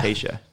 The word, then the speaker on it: Tayshia: Tayshia.